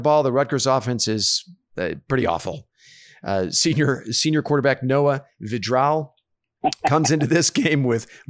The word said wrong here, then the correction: Vedral: Actually, I think his last name is pronounced Vedral. Last week against Indiana, Vedral completed 21 of 34 pass attempts, but only for 130 yards.